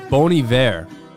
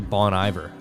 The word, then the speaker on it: Bon Iver: It's Bon Iver. But I used to say Bon Iver. I think a lot of people say Bon Iver.